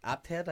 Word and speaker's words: Apted: So it's 1994 and it's directed by Michael, probably saying this wrong, Apted. – Yeah, that sounds right. – Aye, that sounds wrong. – It's APTED.